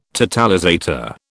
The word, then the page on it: totalizator: British English